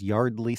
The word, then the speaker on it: Yeardley: it, I guess it technically has the word year in it, but you don't pronounce it that way because the actress's name is Yeardley Smith.